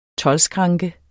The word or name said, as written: toldskranke